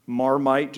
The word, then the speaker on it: Marmite: Marmite, just it's spelled just like it sounds.